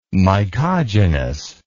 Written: mycogenous

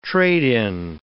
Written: trade-in

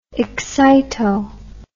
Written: excito

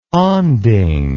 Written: onding

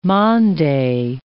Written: Mande

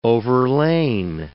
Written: overlain

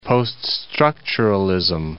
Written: post-structuralism

